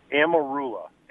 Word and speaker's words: Amarula: And how do you say it again? – Amarula. A-M-A-R-U-L-A.